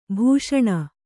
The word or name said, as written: ಭಾಷಣ